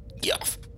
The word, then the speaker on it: Geoff: The G is silent. Geoff!